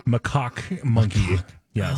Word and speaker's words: macaque: Macaque, I believe, is the. I think it's an emphasis on the A there. Macaque. Let me play it here officially. – Macaque monkey.